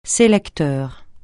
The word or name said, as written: sélecteur